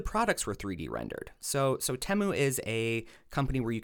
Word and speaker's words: Temu: So my next one is what I thought was Temu. However, we learned very quickly from the earworm jingle that it is Temu. So they instantly told me that I was saying it wrong, so I didn't love that.